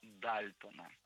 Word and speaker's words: Дальтон: Первая революция связана с работой, ну, наверное, вы, может быть, догадались или нет, не знаю, но с работой Джона Дальтона. – Я надеюсь, нет. – Я правильно ставлю его ударение. – Дальтон, все правильно, Дальтон.